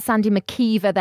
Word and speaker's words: McKeever: Let's talk about Sandy McKeever then, from Clemson Tigers, because that's how you say it. Because of, how do we know this, Kieran? – Because she's made it known on social media.